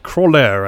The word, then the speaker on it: Croixleur: I just looked up, which is a game I still don't know how to pronounce the name of, which is Crawlier, I think is how you pronounce it, which is spelled C-R-O-I-X-L-E-U-R. – X is definitely silent.